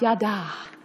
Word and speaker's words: yada: Now, for the Bible scholars in the room, it is correctly pronounced yada. But I have more fun with it the other way.